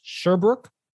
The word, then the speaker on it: Sherbrooke: Eidos Montreal and Eidos Sherbrooke, I believe is how is how you pronounce that uh, second studio's name.